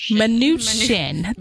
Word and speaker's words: Mnuchin: Treasury Secretary Stephen Mnuchin. I can't say his name. – Mnuchin. – Thank you.